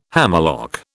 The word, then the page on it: hammerlock: British English